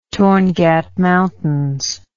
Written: Torngat Mountains

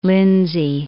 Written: Lindsay

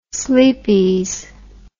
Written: Sleepeze

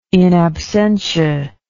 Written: in absentia

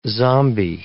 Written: zombie

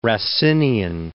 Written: Racinian

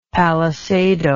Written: palisado

